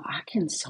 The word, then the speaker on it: Arkansas: Und die Aussprache, denkt man auch mal, Arkansas. – Komisch, so, ne? Wie kommen die drauf? Es steht ja, doch Arkansas.